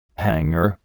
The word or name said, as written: hangar